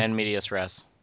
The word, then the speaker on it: in medias res: in medias res? Is that how you say it? In the middle of the story, basically? – Yeah, in medias res.